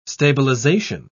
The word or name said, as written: stabilization